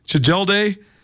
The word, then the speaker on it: Tjelde: Jeff Tjelde, yes, I think is how you say it, Tjelde, T-J-E-L-D-E.